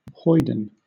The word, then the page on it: hoyden: British English